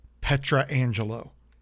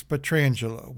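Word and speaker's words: Pietrangelo: It's when opposition announcers and people at NBC Sports pronounce Alex Petrangelo's name Petrangelo. His name is Alex Petrangelo. Whether he made a mistake, please call him Alex Petrangelo.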